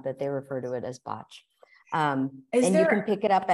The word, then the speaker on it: Bach: And technically, I know we all call it Bach, but it's, yeah. – it's apparently pronounced botch.